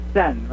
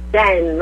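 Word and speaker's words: Tseng: Now, what about, I mean, if Yanni Sen, and you know in Hawaii we call her Sen. I don't know what it is, I don't know why, but it is Sen, right? Pronounced. – Sen, right, but yes. Sen, like you're... State of Zen.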